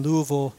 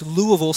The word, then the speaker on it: Louisville: So if you have one of those bats, it's Louisville Slugger. If you can say it in one syllable, like all the better.